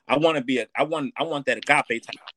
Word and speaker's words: agape: So often we hear people saying, I want, is how you pronounce that ape gauge? – Agape.